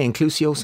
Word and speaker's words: Inclusio: Am I Inclusio, Sandra? – You are indeed, Richard.